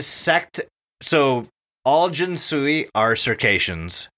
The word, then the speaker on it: Circassians: Circassians. – The Circassians.